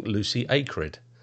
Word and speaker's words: Lucy Acred: And today I'm interviewing Lucy Acred.